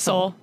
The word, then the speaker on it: Seoul: Seoul.